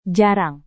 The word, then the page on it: jarang: Indonesian